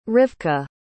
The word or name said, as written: rivka